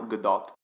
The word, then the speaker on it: Gadot: Which I did learn the pronunciation, finally, after 10 years. It is Gal Gadot. – Really? – Yeah. – Good to know. – The T is not silent.